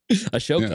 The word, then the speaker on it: Ahsoka: Ahsoka. – Ahsoka.